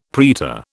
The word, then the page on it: praetor: British English